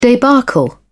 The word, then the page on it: debacle: British English